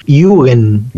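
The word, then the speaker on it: Yugen (幽玄): Che, Jugend está bien decirlo. Sí. Me encanta. – Es... La pronunciación más correcta del japonés sería como con i latina u, así tipo un yugen.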